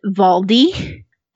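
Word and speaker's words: Waldi: This is where I got really messed up, because the girl's German and she pronounces it Valdi. And I was like desperately typing V-A-L-D-I into the search.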